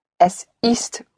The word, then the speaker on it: es ist: Yes, but one has to hear all consonants, all sounds in German are clearly pronounced even when they're unstressed. The T has to be heard. Es ist.